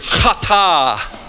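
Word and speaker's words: hata (חטא): The word is hata, hata. You want to say it right? It's hata.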